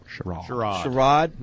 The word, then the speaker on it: Sherrod: Is that how he's pronouncing it? – Yes. – Is that correct? – That's how he's pronouncing it. – Is that correct? Should Sherrod. He be pronouncing it that yes. – way? – Eric-like. Okay.